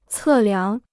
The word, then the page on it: 测量: cè liáng